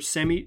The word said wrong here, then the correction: Semi: I think it's Shemi actually. I was pronouncing it wrong.